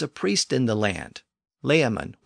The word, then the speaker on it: Layamon: His name was Layamon, probably pronounced more like Lachaman at the time, based on the way he spelled his name and nothing is really known about him other than what he tells us in the opening lines of the text.